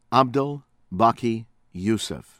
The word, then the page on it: Abdel Baki Youssef: from Kurd